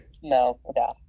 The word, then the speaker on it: Malcadox: Malcadox.